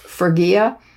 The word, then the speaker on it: Phrygia: Phrygia.